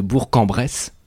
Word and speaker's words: Bourg-en-Bresse: Trouvez-nous les deux autres personnes qui nous écoutent de Bourg-en-Bresse.